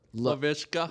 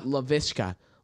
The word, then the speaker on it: Laviska: Laviska. – Laviska.